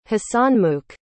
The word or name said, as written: hasanmukh